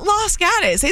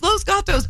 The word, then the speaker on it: Los Gatos: It's Los Gatos. We know. – Los Gatas? – Who said that? – No, we all would be like, in Las Gatas. It's not Las Gatas. It's Los Gatos, but we're not...